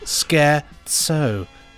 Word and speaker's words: scherzo: It's pronounced scherzo. There we go. It's scherzo. The Italian word for joke.